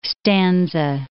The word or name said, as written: stanza